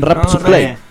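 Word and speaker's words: Rapsusklei: ¿Cómo se pronuncia? – Creo que es así, ¿no? – Rapsuclay. – Rapsuclay. – En español. – Claro.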